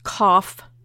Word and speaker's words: Qof: And the second letter is, it's spelled S-A-M-E-C-H. Or sometimes it's spelled S-A-M-E-K-H. And from what I understand, it's pronounced Samic. And the third letter is pronounced Kof. And it's spelled Q-O-F. Or sometimes it's spelled K-U-F.